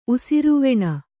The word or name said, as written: ಉಸಿರ್ವೆಣ್